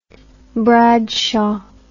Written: Bradshaw

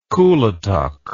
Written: kuletuk

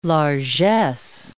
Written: largesse

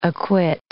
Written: acquit

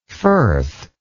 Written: Firthian

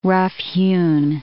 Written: rough-hewn

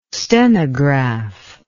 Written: stenograph

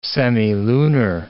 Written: semilunar

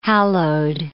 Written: hallowed

